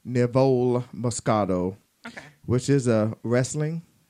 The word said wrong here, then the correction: Riesling: Is that Riesling? How you pronounce it? – Riesling.